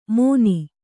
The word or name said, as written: ಮೋನಿ